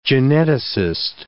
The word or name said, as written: geneticist